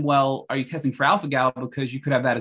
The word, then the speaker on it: alpha-gal: It's alpha-gal, A-L-P-H-A-G-A-L syndrome, alpha-gal syndrome.